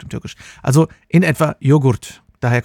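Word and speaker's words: yoğurt: Das Wort Joghurt kommt vom türkischen Joghurt, was fast genauso geschrieben wird, aber in etwa Joghurt ausgesprochen wird.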